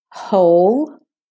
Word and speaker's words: whole: And there are some words that have WH at the beginning and the W is silent there. Whole.